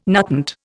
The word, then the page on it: nutant: Indian